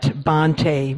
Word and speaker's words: Bhante: And then for those folks on Zoom, you can contribute at through Venmo, and it's at Bhante, which is spelled B-H-A-N-T-E.